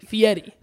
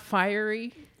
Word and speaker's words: Fieri: Fieri. – Yes. – Fieri. – That's how he pronounces it, and you should respect the man. – Not fiery. – No, Guy Fieri.